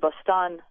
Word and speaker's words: Bostan: and Bostan, spelled like the city on the American Northeast.